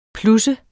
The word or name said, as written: plusse